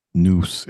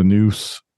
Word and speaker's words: nous: I was just going to kind of read what noose is, or noose. N-O-U-S is how it's spelled.